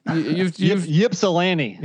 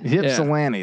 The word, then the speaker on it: Ypsilanti: Well, how do you say that? – Ypsilanti. – Ypsilanti. Yeah. That's what it is.